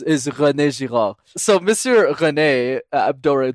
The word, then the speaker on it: René Girard: So the English, I guess, pronounce it, which is wrong, is René Girard.